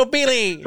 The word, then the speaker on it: Ginobili: Ginobili.